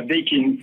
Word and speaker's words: Daikin: On dit bien Daikin parce qu'on se pose la question sur la prononciation. – C'est absolument ça, Daikin, c'est voilà.